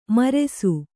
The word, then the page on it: ಮಿರಾಸಿ: Kannada